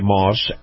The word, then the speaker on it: Maus: Okay, now, this portion of the program, my conversation with Chris Markowski, the watchdog on Wall Street, is brought to you by Moss Acura. Now, Moss is spelled M-A-U-S,